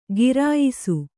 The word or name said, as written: ಘೇರಾಯಿಸು